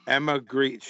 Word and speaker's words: Grede: Her name is Emma Greed, but not Greed. You don't spell it that way? – That's great. – G-R-E-D-E. Emma Greed. She's mad cute. G-R-E-D-E.